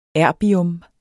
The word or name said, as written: erbium